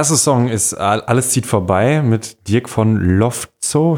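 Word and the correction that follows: Lowtzow: spricht man ihn so aus? – Äh, Lotzo einfach. Lo, Lotzo. Aber er hat sehr viele Ws in seinem Namen.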